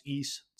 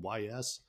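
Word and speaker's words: Ys: I believe it's pronounced East, spelled Y-S. – Yeah. I don't know. I said Y's and I was obviously wrong.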